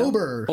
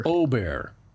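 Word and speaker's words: Ahgren: Ludwig, how are we pronouncing his last name now? – Ober. – Ober. Begins with an A for our eyes, but it's just a no. Long, yeah, long no.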